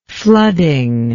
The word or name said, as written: flooding